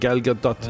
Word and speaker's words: Gal Gadot: I looked it up. That's how you pronounce it. Gal Gadot.